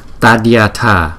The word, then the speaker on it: Tadyatha: Tadyatha, Tadyatha, Tadyatha. Chữ thờ bình thường như là thờ của tiếng Việt Nam đó.